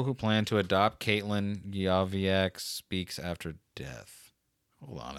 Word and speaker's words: Yozviak: Joey Yozviak. – Spell that last name for me, please. Thanks. – Y-O-Z. – Wait. Y-O-Z. – V-I-A-K.